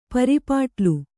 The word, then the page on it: ಪರಿಪಾಟಲು: pari pāṭalu